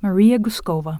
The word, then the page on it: Maria Gouskova: məˈɹijə ɡuˈskoʊvə